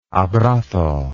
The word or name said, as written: abrazo